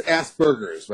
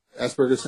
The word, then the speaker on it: Asperger's: It's pronounced Asperger's, right? Asperger's syndrome.